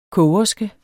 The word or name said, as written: kogerske